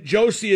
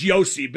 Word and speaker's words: Josi: Uh, if it ain't Jones, then Josi is the best uh, defenseman in the National Hockey League. Actually, it's Josi, but I've made it clear I don't care when I mispronounce.